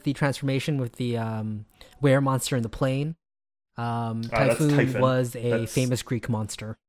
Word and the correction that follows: Typhon: Isn't that Typhon, not Typhoon? – Typhon, sorry, yeah.